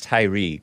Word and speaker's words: Tyre: I sometimes say Tyre, but it's Tyre, guys. Out of Memphis, Tennessee.